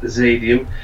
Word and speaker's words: Xadium: Their name on Twitter is it's spelled XADIUM, pronounced Zadium. Their EP is out at the moment.